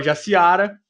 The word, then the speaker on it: Jaciara: Na verdade, eu nem sei se é Jaciara ou se é Jaciara, alguma coisa assim. Como é que é seu nome? é Jaciara. – Ah, então eu falei certo.